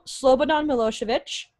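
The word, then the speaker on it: Slobodan Milošević: In February of 2002, Slobodan Milosevic begins his trial. – Wow. – Yeah, that's how you say it.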